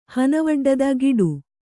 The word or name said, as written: ಗೀಟು